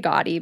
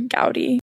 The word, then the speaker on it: Gaudi: I say Gaudi, but I think it's Gaudi. – Yeah, Gaudi.